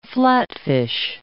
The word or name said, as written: flatfish